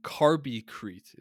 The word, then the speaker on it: CarbiCrete: So um, what is what is carby carbicrete? is that correct pronounced that's right? correct yeah so it's it's yeah. basically it's it's basically two words it's carbon and and concrete right so we're,